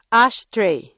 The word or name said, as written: ashtray